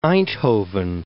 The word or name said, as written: Eindhoven